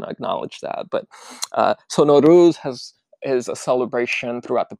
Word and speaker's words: Nowruz: I asked Aresh if I was pronouncing Nowruz right. And he was just very kind. And he was like, oh, no, you know, there's different ways of pronouncing it. But Nowruz is the proper way. I was saying Nowruz.